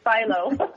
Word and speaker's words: Philo: It's Philo.